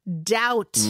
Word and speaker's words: doubt: doubt, D-O-U-B-T인데 B는 음. 묵음이죠.